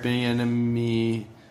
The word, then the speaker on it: Bieniemy: Eric Bieniemy is how you pronounce that, is coming in.